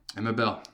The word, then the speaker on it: Emma Bell: Emma Bell.